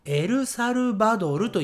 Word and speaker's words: エルサルバドル: エルサルバドルと言いますエルサルバドルはい、